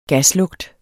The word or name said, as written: gaslugt